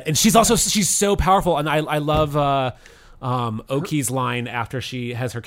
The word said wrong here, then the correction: Okoye: Okoye, I don't know how to pronounce her name.